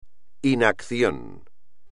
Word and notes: inacción: espagnol

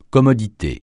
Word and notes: commodité: francais

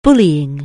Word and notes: bullying: anglais

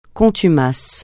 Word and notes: contumace: francais

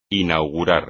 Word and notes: inaugurar: espagnol

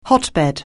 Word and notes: hotbed: anglais

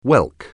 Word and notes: whelk: anglais

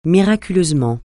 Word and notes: miraculeusement: francais

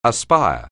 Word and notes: aspire: anglais

